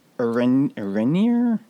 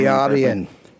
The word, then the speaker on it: Eärendil: During that scene between Isildur and Arrhenion? I am not pronouncing it. Eärendil.